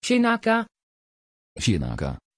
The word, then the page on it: chinaka: Dutch